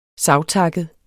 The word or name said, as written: savtakket